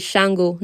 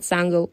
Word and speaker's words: Shango: So the correct pronunciation for this god, this Orisha, is Shango, not Sango.